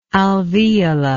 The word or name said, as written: alveola